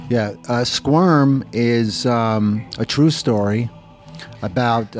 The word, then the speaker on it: Squirm: Donc Squirm en VO, je ne sais pas si je prononce bien. – Squirm. – Comment tu dis ? – Squirm. – Squirm. – Squirm. – Ok, d'accord.